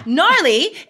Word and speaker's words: gnarly: It starts with a G. Like, what is that about? I mean, English is made up of so many other languages. I'm totally going to figure this out soon. So yeah, gnarly is spelled G-N-A-R-L-Y.